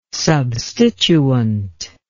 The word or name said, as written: substituent